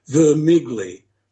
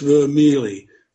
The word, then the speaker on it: Vermigli: But everybody pronounces his name as it reads, Vermigli. But in Italy, it's Vermeely. But I've never heard a scholar speaking about this man say Vermeely, which is the correct Italian pronunciation.